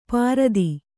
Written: ಪ್ರತಿ

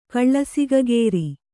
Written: ಕಳ್ಳಸಿಗಗೇರಿ

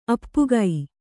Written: ಅಪ್ಪುಗೈ

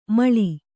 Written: ಮೇಳಿ

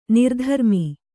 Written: ನಿರ್ಧರ್ಮಿ

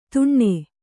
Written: ತುಣ್ಣೆ

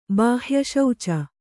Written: ಬಾಹ್ಯಶೌಚ

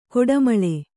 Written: ಕೊಟ್ಟೆಮುಳ್ಳು

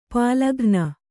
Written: ಪಾಲಘ್ನ

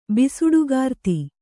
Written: ಬಿಸುಡುಗಾರ್ತಿ

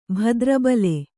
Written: ಭದ್ರಬಲೆ

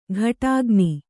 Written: ಘಟಾಗ್ನಿ

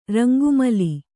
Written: ರಂಗುಮಾಲೆ

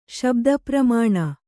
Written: ಶಬ್ದಪ್ರಮಾಣ

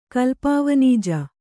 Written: ಕಲ್ಪಾವನಿಜ

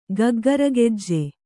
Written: ಗಗ್ಗರಗೆಜ್ಜೆ

